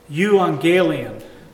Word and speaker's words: euangelion: The word gospel in the Greek language is pronounced euangelion, and it means a good message.